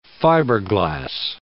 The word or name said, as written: fiberglass